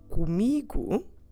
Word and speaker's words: comigo: But let's stress, as we always do in Portuguese as a general rule, let's stress the second to last syllable in both of them. So with me, comigo, and because the stress syllable is me, both O's go unstressed.